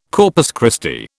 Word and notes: Corpus Christi: British English

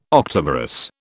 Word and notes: octamerous: British English

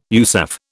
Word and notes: USAF: British English

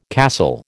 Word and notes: castle: American English

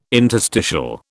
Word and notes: interstitial: British English

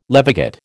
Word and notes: levigate: American English

